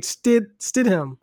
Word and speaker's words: Stidham: I can't say his name. Stidham. It's that D right there before the H. Stidham.